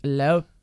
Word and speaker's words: Loupe: And L-O-U-P-E. – Yeah. – Actually, the last name is more Cajun than anything else than Spanish. That's where the... And so it's actually Loup. That's how you pronounce it, but it's just Loup. L-O-U-P-E.